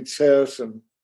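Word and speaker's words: Terezín: And the Czech pronunciation would be Tersen.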